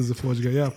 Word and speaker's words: Zifodya: Yeah, most people are Dr. Z, but Dr. Zifodya.